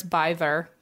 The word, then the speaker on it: Byther: And also I made sure it's Byther because it's spelled like bither.